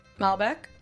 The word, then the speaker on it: Malbec: And I pulled up Malbec. Mm-hmm. I'm saying that right. – Yes.